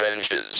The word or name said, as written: benches